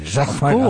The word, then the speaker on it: Jacquemart-André: You say it like André. – A Frenchman. – Jacquemart-André. Oh, it's because I'm French.